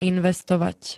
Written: investovať

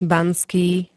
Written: banský

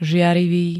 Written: žiarivý

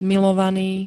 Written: milovaný

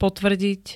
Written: potvrdiť

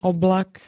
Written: oblak

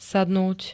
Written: sadnúť

